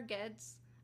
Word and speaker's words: Geddes: It was Governor Geddes, I believe is how it's pronounced. It's G-E-D-D-E-S.